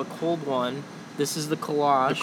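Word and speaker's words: Kölsch: So it's Kolsch is the actual pronunciation, but I'd say most people just call it Kolsch.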